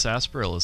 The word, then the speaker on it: sarsaparilla: I don't think I'm pronouncing correctly. – No, that's right. – It's pronounced sarsaparilla, Steven.